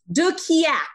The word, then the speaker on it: de Quillacq: De Quillacq.